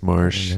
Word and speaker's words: Maresch: Is it Chris Maresch or Marsh? – I've always called him Marsh. – I think it's Marsh. It's spelled Maresch. – Maresch. – That's Marsh.